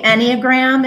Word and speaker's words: Enneagram: And it was, there's a lot going on here how do you say the word again? Enneagram? – Called the Enneagram.